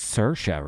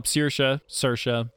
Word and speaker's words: Saoirse: But I think it's pronounced Saoirse, right? – Well, Saoirse, yeah.